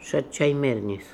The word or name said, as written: Shat Chai Mernis